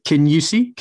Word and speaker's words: Kingussie: I would call it like Kingussie. – Can you see? – Yep. Perfect.